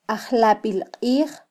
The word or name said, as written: ajlabilqij